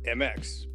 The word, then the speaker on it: Emecz: It's owned by a man named Steve, whose last name is MX, but he spells his last name E-M-E-C-Z. – Nice. – And it's pronounced MX.